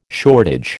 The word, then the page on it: shortage: American English